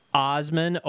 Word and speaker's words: Assman: So it's apparently pronounced Osman, O-S-S-M-E-N, but it's spelled A-S-S-M-A-N.